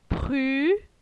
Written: Pru

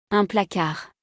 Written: Un placard

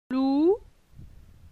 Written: Lou

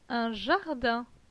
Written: Un jardin

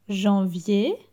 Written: Janvier

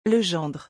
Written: le gendre

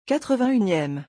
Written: 81eme

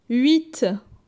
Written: 8

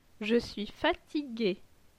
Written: Je suis fatigue

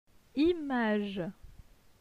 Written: Image

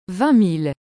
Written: 20000

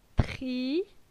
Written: Tri

